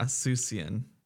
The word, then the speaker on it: Seussian: Spell it. – S-E-U-S-S-I-A-N. – Wait, S-E-O, like Dr. Seuss? – Yeah, a Susian.